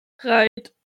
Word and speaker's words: Reit: Äh, wer hat das ausgesprochen? Ried? – Reit. – Reit, Reit. – Also wie es geschrieben okay. ist.